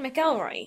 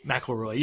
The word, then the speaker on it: McElroy: Justin McElroy. You talked to on McElroy. – Facebook. – McElroy. Yes, it looks like it's spelled McElroy. But it's McElroy.